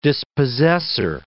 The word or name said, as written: dispossessor